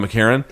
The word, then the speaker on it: McEachern: It's spelled Lon, yes. McEachern, but it's pronounced Lon McCarron.